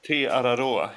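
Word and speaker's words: Te Araroa: Te-Araroa heter den.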